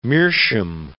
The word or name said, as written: meerschaum